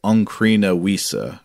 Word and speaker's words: Ancrene Wisse: Uh, but Simon says it, the Oncrene Wies popped up and you pronounced both words with a silent final E. The consensus pronunciation would have those final vowels voiced rather like a German speaker might pronounce Porsche, uh, I think. So I believe it would be Onkrina Wiesa, I guess, if, if Simon is correct.